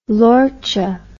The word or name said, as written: lorcha